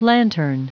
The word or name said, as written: lantern